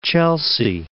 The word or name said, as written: Chelsea